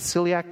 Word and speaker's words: celiac: is it celiac disease? Is, am I saying that right? Okay, it is so that if she just has con- contamination of any sort, she gets violently sick.